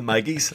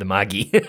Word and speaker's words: Magi: I remember him telling us once, it's a hard G, Latin doesn't have a soft G, and the entire class promptly ignored him and said, we're not going around talking about the Maggie. So Magi is.